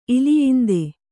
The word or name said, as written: ಇಲಿಯಿಂದೆ